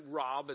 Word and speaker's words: Raub: But my name's Kevin Raub. It's spelled R-A-U-B.